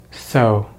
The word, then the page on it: so: American English